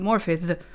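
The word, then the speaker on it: Morfydd: Morphyth. Jag har googlat hur man eh, ja. – uttalar det. – Det är Morphyth. väldigt komplicerat. – Morphyth. Morphyth. Det är som ett th.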